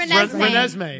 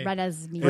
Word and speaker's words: Renesmee: Rensalini. – What is it? – Rensami. – It's pronounced... – Renesmee. – Renesmee.